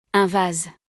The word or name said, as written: un vase